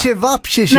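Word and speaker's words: ćevapčići: Ja, men jag har, nu vet inte jag hur man uttalar, men selviche, köttfärskorvar, typ-ish. – Mm. Okej. – Menar du salsiccia? – Det är inte chivapchichi då? Chivapchichi. – Ja, så, precis.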